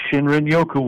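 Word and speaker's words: shinrin-yoku: Shinrin Yoku? – That is correct, Shinrin Yoku, which translates fairly well, I was going to say loosely, but a little fairly well, to forest bathing.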